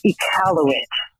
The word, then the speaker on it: Iqaluit: I just wanted to correct your pronunciation in Christian love. It's the city of Iqaluit in Northwest Territories where the graves were found. Iqaluit.